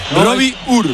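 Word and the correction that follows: Robbie Ure: A ver, el club pasó incluso un audio, lo ha pasado. Y en concreto sería Robi Ui. Robi Ui.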